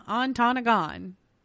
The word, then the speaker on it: Ontonagon: On November 20th, 1987, Dahmer encountered a 25-year-old man from Ontonagon, Michigan. Did I say that right? Ontonagon. – Yeah. – Ontonagon. Sounds good to me.